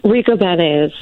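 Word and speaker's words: Ricobene's: Is it how do you pronounce it? Rico Beans? – Rico Benes.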